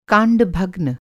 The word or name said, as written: कांडभग्न